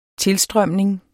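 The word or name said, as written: TILSTRØMNINGEN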